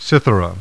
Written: Cythera

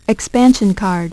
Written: expansion card

